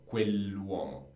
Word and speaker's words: quell'uomo: So quell'uomo, and you should pronounce it perfectly. When we have an apostrophe in Italian, it becomes like a very long word. So quell'uomo.